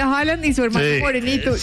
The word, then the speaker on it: Haaland: Porque aquí lo dijimos desde hace varios días, que era Haaland.